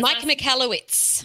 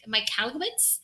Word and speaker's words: Michalowicz: It's called Profit First by Michael, I don't know how to say it. – Mike Michalowicz. – Michalowicz? – Yeah.